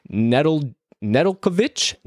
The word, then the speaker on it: Nedeljkovic: It looks like it's going to be Alex Nedeljkovic, wanted to say that correctly.